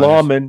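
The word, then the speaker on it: lamina: Among lawman. Us? Lawman is really the pronunciation for Latin.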